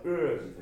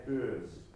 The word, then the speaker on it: urge: Voices、そして that、その次の言葉はちょっと発音しにくいですが、これは urge です。はい、 urge。はい、曖昧母音のうーって音から下がちょっと上に上がる感じで、うーって響きにちょっと変わっておいて、じって音です。